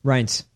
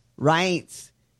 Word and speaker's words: Reince: Reince. – Reince.